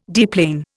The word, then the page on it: deplane: Indian